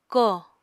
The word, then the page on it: ko: Apatani